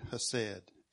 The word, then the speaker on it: hesed: It's the Hebrew word hesed, H-E-S-E-D, and I don't pronounce it right. Sounds like a cat kind of coughing with a fur ball, the way they put a K in front of that.